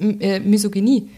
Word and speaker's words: Misogynie: Das ist Misogynie.